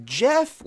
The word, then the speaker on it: Geoff: And it's Jeff, like my mind wants to say Geoff because it's spelled G-E-O-F-F.